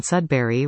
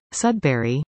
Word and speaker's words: Sudbury: Doug McCann in his book The Story of the Birth of Broadcasting in Sudbury relates this story about CKSO-TV's first news anchor, Bill Kyo.